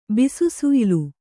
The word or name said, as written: ಬಿಸುಸುಯ್ಲು